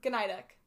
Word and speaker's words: Gnidek: Gnidek. Gnidek? You pronounce every letter you see. – I thought it was Gnidek. – No, you pronounce the G. A lot of people think it is Gnidek, but...